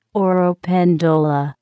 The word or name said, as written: oropendola